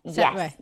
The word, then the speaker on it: Sattva: So I know that you are a Sattva, is that how you say it? Sattva yoga teacher? – Sattva, yes. Yeah.